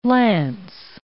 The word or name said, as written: lance